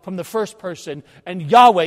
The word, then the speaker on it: Yahweh: Often pronounced Yahweh or Yehwah.